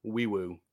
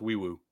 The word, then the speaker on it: Nwaiwu: N-W-A-I-W-U. Wiwu. The N is silent. – Dude, you lost me, but keep going. – No, it's pronounced. It's pronounced like, or well, it's spelled like No I Woo, but it's pronounced Wee Woo.